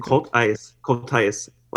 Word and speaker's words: Cultice: I wish it was Coltice. It's okay. Coltice.